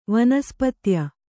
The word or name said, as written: ವನಸ್ಪತ್ಯ